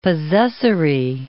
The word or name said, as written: possessory